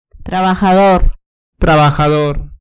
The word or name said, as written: trabajador